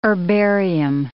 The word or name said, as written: herbarium